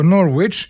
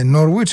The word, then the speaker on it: Norwich: Πάμε τώρα στην Αγγλία, στο Norwich ή Norwich όπως το λένε πιο πεταχτά και με άλλη προφορά φυσικά οι Εγγλέζοι.